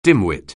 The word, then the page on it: dimwit: anglais